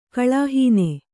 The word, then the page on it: ಕಳಾಹೀನ: kaḷāhīna